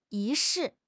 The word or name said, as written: 仪式